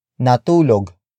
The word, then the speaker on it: natulog: Natulog ka na ba? Natulog ka na ba? Natulog ka na ba? It means, have you slept already? Word for word it's, natulog ka na ba.